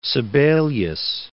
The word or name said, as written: Sibelius